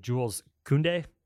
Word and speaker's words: Jules Koundé: Apparently, as of this morning, excuse me for the pronunciation, Jules Koundé, is that how you say his name? – Yeah.